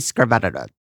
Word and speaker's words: Skretvedt: It's actually spelled S-K-R-E-T-V-E-D-T. – Skrevedit? – Too many consonants. – Seriously. – Randy Skrevedit? – That's right. Randy Skrevedit.